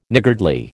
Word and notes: niggardly: American English

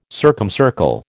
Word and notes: circumcircle: American English

